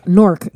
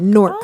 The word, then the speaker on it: Newark: Newark. It's like fork with Newark. An N. – Yeah. – Oh, is that how you pronounce it? – That's how you pronounce Newark. Newark.